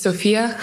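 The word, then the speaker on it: Sofía: My name is Sofia Jasmine Colombo Abdullah. It's Sofia con una, with an A. There's an accent over the I, but I go by Sophie because people never say it right.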